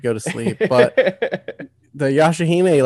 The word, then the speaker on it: Yashahime: Yeah, Yashahime.